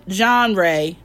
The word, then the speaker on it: genre: If so, what genre? That's how I say it. How do you say that word? – I think you're saying it right.